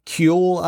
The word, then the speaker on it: Kuehle: The LA County supervisor, a woman named Sheila Kuehle, I believe is how this is pronounced. K-U-E-H-L-E is how you spell her last name.